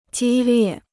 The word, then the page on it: 激烈: jī liè